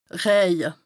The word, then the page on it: rëje: Bas-Rhin; Strasbourg